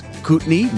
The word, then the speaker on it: Kootenai: It is Kootenai, not Kootenai or Kootenai, which is, I am told, the Canadian pronunciation, eh?